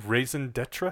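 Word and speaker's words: raison d'être: Raison d'etre, okay. I believe, is how it would be pronounced.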